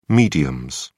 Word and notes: mediums: British English

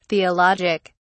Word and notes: theologic: British English

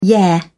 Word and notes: yeah: British English

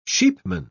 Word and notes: sheepman: British English

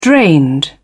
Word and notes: drained: British English

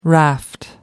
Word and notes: raft: American English

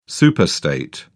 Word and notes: superstate: British English

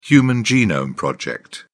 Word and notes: Human Genome Project: British English

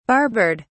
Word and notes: barbered: British English

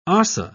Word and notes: ossa: British English

